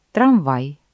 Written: трамвай

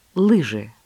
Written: лыжи

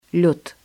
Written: лёд

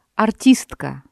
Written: артистка

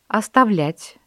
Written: оставлять

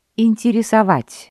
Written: интересовать

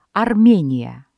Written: Армения

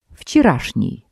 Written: вчерашний

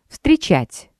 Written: встречать

